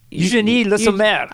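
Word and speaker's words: Eugénie Le Sommer: Eugenie Le Sommer.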